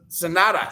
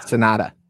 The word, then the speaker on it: Sanada: Uh, Sanada. – Sanada. Sanada versus Jack Perry for the IWGP World Heavyweight Championship. Tanner has Sanada for nine. – I got Sanada for 11. – I have Sanada for five.